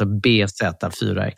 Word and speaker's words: bZ4X: elbil BZFX, heter den. – Beyond Zero 4X. Alltså... – Den heter alltså BZ4X. Ja. BZ4X.